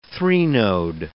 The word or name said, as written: threnode